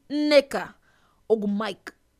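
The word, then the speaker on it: Nneka Ogwumike: The sisters to Aneka, people say her name's Aneka. Her name's Aneka Ogumike. That's how you say it. That's how you say it in Nigerian way.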